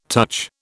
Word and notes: touch: British English

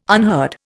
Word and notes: unheard: Indian

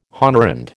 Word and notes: honorand: American English

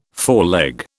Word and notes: foreleg: British English

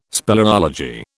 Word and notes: speleology: British English

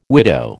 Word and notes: widow: American English

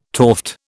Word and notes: toft: British English

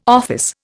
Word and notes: office: Indian

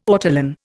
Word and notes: ortolan: Indian

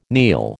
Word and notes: kneel: American English

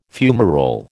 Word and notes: fumarole: American English

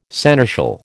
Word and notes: seneschal: American English

